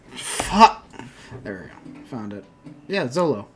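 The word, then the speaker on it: Xolo: I thought it was Zolo. – Zolo would start with a... I think X is a sh sound.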